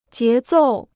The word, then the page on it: 节奏: jié zòu